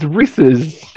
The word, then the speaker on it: Reese's: It's spelled like Reese. It's Reese's. – Anyways, I want to try these... Reese's. Reese's. – It's Reese's. – R-E-E-S-E-S. That's what we're saying. – Apostrophe S. It's Reese's Cups.